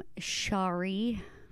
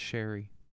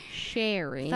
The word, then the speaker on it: Shari: That is how I'm pronouncing it because that is how it is spelled. – Could it be Sherry? – Could it be Sherry? I'm not pronouncing it like that because it is literally spelled Shari. – That's Sherry. – Ooh, that's not. – Yeah. – Okay. Anywho, Sherry. – Fine, Sherry. Sherry.